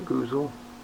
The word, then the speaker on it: goozle: Do you ever call it the goozle? – That's right. – How did they used to say that? Am I pronouncing it right? – Goozle.